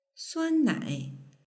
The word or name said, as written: suannai